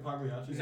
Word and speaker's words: Pagliacci: Wait, does anyone here work at Pagliacci's? – No, members of Josh. – Half the members. And if they did, they tell you that the G is silent.